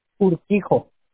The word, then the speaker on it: Urquijo: Urquijo.